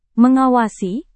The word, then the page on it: mengawasi: Indonesian